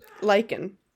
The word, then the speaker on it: lichen: Well, how do you pronounce it? – Lichen. – Lichen. Okay.